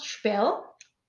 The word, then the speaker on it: Sperl: So it's pronounced Spell. – Yeah. – But it's written Sperl.